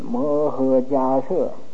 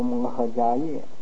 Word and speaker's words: 摩诃迦叶: 这个、大目犍连摩诃迦摄啊，你们记得这个本来是个叶字，但是要读成舍字啊，摩诃迦摄，有的人呢不懂的呢就读摩诃迦叶，这是读错字的啊，